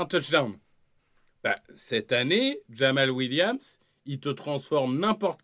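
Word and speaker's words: Jamaal: J'ai peur d'écorcher son prénom, mais c'est Jamal. Hein, si Jamal, exactement.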